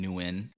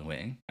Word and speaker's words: Nguyen: You know, even kind of like us saying our name, like, fucking my last name, Nguyen. – Right. – I could say, like, oh, Nguyen. I don't even know if I'm saying it right.